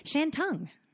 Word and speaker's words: Shantung: All right. Shentongue. Shantung? I have no idea what you're saying. – Gosh, not really close.